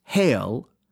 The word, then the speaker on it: hele: And it never occurred to them that hele, H E L E, meaning conceal, and rightly pronounced hail, was sacred because it was old and venerable, and came to us as a touch with the days of yore.